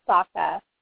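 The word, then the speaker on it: Socha: It's Sokka. It's like soccer. – Sokka.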